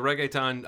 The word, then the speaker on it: reggaeton: You may have been watching the reggaeton remix of Breakfast Club. Did I pronounce reggaeton correctly? – Yeah, okay.